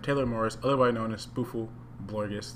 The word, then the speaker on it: SpoofelBlorgus: Did I say that right? Pretty sure I said that right. – Yeah, it's better than most people go for. – How do most people usually say it? – Spiffle. I got spiffle once. There's no I.